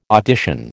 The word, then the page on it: audition: American English